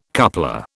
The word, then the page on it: coupler: British English